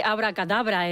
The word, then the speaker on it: abracadabra: ¿cómo se dice abracadabra en francés? – Ah, bueno. – Yo creo que no. – ¡Abracadabra! – Eso es. Con el acento al final.